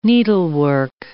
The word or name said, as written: needlework